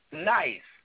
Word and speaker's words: Ny$$e: She performs under the stage name of Nice, pronounced Nice. It looks more like the New York Stock Exchange, but we're going to talk about that later.